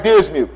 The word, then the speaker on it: Dismuke: Greg Dismuke. Who? Greg Dismuke. How do you spell that last name? D-I-S-M-U-K-E.